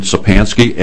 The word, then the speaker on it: Szczepanski: My name is David Sapansky, S-Z-C-Z-E-P-A-N-S-K-I.